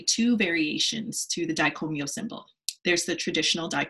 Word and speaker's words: Dai Ko Myo: So anyway, for what it's worth, Dai Ko Myo is pronounced Dai Ko Myo. So really fast, Myo. I've heard Dai Ko Myo, Dai Ko Myo, it's Dai Ko Myo.